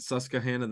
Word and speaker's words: Susquehanna: I don't want to pronounce the Q too much. We'll have to get the locals up there. We don't want to get them mad. Susquehanna, the Susky, as people call it.